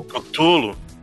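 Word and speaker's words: Cthulhu: e que vai ser aquele Tilulu, como é que é? Não sei nem como é que fala esse negócio. – Ele é um... É Cutulo. – Isso, é Cutulo mesmo? – É, é, na verdade, é.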